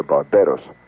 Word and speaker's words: Barbados: And I'm talking, in this case, of Barbados, as it's pronounced down here, Barbados.